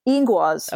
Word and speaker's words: Ingwaz: Is it Ingvats or Ingwats? – Ingwaz.